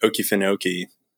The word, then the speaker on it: Okefenokee: because I came across The Farm at Okefenokee, if I'm saying that right.